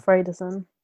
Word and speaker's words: Alfredson: Alfredson.